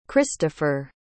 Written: christopher

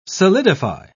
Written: solidify

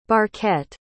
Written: barquette

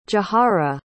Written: jahara

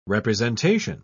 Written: representation

